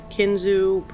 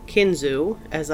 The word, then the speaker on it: Kinzua: I do want to point out I have heard the word Kinzu pronounced two different ways. Kinzu, as I have just said it, and Kinzua, which I read was the way that some Native Americans say the word. It is spelled K-I-N-Z-U-A. So I may interchange the two pronunciations.